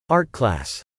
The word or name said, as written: Art class